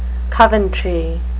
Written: coventry

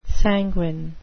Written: sanguine

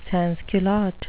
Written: sansculotte

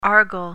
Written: argal

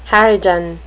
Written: harridan

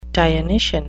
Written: dionysian

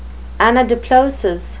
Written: anadiplosis

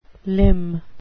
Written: limn

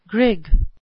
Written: grig

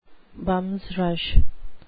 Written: bums rush

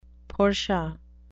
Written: portia

